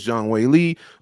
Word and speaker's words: Zhang Weili: It is Zhang Weili officially.